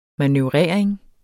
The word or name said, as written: manøvrering